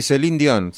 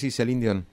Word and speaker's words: Céline Dion: Sí, Céline Dion, sí, Céline Dion. – Ahí estamos, mire con quién, esperemos que esté bueno el sonido, pero me llamó la atención, Céline Dion con los Bee Gees.